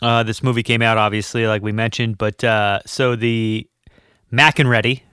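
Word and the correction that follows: MacReady: is his name, right? – McCready. – Yeah. Oh God. – McEnready. You're writing your own movie over here. I butchered that.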